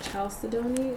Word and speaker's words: chalcedony: And so onyx is a stone of inner strength, and it is a variety of chalcedony.